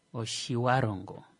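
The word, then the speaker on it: Otjiwarongo: The one guy, he explained that Oshivarongo's actual pronunciation should be Oshiwa. – Oshiwarongo. Yeah. It's like, just. But it's not a W. It's not supposed to be a W pronunciation. Yeah. It's UA. It's like a UA.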